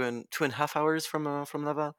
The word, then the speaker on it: Laval: And it's called Laval, is that or am I saying that correctly? – Yeah it's a small town.